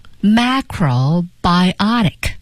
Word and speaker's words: macrobiotic: 接下来咱们再看一个有趣的单词， macrobiotic。 – 首先我们来看它的发音啊，这个大家呢来标一下，把重音放在 biotic 这个地方。